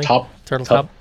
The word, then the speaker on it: Turteltaub: This is coming from director John Turteltaub, if I'm saying that correctly. – Turteltaub.